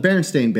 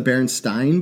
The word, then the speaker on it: Berenstain: Shouldn't we be calling them the Berenstain Bears? And then people were like, no, it's spelled Stein, but you pronounce it Stain.